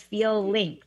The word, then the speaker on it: Feel Links: It sounds like feelings, but it's Feel Links, right? – Exactly.